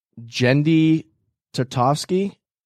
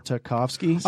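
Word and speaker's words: Genndy Tartakovsky: How do you pronounce this? Jendi Tartakovsky? – Yeah. – Sure. Tartakovsky.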